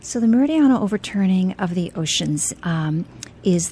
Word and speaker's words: meridional: And I knew I was going to butcher that word. You're very close, Ernie. Yeah, meridional. – Meridional.